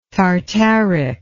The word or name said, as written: tartaric